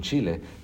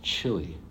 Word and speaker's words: Chile: He stopped me, held up his hand, and said, it's pronounced chili. And I thought, okay, but I went on, and the next time again when I said Chile, he once again stopped me. I'll never forget that. Like, he wasn't, I think, trying to be insensitive. He maybe had my best thought in mind, thinking he needs to learn to speak like an English speaker. – Yeah. He just thought you were saying it wrong, which in reality you weren't.